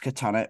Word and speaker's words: Chthonic: And Bal-Sagoth are dropping what is thought to be their last ever album, The Catonic Chronicles. – Many thousands of years ago, they ruled the globe. – It's pronounced by Baron as Catonic, but that isn't how it's spelled.